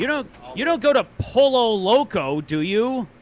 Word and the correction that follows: Pollo: It's Pollo. – Pollo. – No. – L's are Y. – Yes. – L's are Y. Yeah.